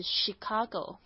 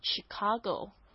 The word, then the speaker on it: Chicago: 是 Chicago。好，那但是呢，在这芝加哥这个本地的地方呢，也有人会讲 Chicago，所以这两个发音都可以的。